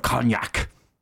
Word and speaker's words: cognac: I always thought you pronounced the G. – Cognac. I was pronouncing the G.